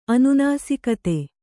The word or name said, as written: ಅನುನಾಸಿಕತೆ